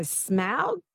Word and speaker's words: Smaug: Apparently you say it like cow, so Smaug, but Smaug, Smaug.